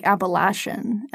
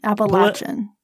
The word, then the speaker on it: Appalachian: Appalachian. One year at our small... – You just mispronounced it. It's not like Appalachian. Appalachian. – Appalachian.